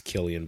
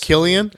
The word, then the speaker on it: Cillian: I think it's Killian, but we'll call it Killian? Is it Killian Murphy, a.k.a.